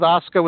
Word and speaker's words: Asuka: Asuka. Just call her Asuka. – Okay. – Asuka, I don't think they're going to... I think the whole idea with Asuka was to have her go 1 year with the NXT women's title and be undefeated.